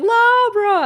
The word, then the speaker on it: La Habra: I would just be like, La Habra, I want to go to La Habra. And my sister had like taught me how to pronounce it with the silent H, because it's technically Spanish or whatever.